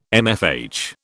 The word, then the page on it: MFH: British English